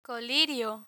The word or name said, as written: colirio